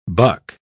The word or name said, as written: buck